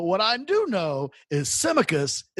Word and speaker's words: Tsimikas: Tsimikas' name correctly. So when he signed, I listened to as many Greek people as possible pronounce the name. Tsimikas seems to be the way